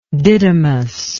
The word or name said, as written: didymous